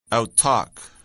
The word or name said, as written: outtalk